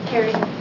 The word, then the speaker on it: Kaire: Can we have Liz Baer, Jessica Dolan, and it looks like Care Dolan? – Carrie. – Is it Care? – It's Carrie. – Oh, Karen. – Carrie. – K-A-I-R-E.